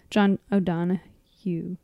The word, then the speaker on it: John O'Donohue: I'd heard of him, John O'Donohue. That's how you say it.